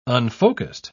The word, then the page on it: unfocussed: British English